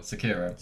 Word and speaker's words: Sekiro: Anyway, Sicario. – I don't even follow us on Twitter. – Did I say it right? No, that's a film. – Sicario. – Sicario. – Sekiro.